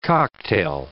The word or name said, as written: cocktail